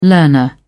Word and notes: learner: British English

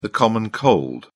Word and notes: common cold: British English

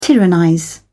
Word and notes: tyrannize: British English

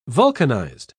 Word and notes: vulcanised: British English

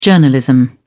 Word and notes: journalism: British English

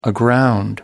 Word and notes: aground: American English

